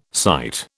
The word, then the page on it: sight: British English